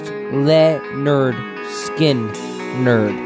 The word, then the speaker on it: Lynyrd Skynyrd: Just remember, it's pronounced Leonard Skinner.